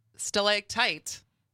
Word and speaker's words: stalactite: Stalactite. – Mm-hmm. – Am I saying that right? – Yeah.